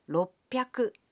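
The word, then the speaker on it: roppyaku: But the pronunciation can be a bit tricky. 300 is pronounced sanbyaku. 600 becomes ropyaku. And 800 is pronounced happyaku.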